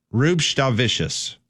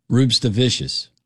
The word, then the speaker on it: Rupstavicius: Rupstavicius. – Emphasize the S, emphasize the C. – Yes. – Rupstavicius.